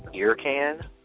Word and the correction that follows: Ear Candy: Production? – Ear Candy, okay. So Ear Candy,